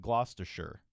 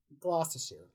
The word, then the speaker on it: Gloucestershire: And so it is spelled G-L-O-U-C-E-S-T-E-R-S-H-I-R-E. – This is easy. – I mean, is it easy? I don't know if you pronounce all the letters there. Is it just Gloucester or is it Gloucestershire? – Gloucestershire. – It is Gloucestershire.